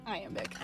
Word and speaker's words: iambic: Iambic.